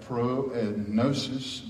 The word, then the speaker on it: prognosis: Prognosis. Gnosis is spelled with a G. It's usually silent until we put pro in front of it, and then it becomes prognosis.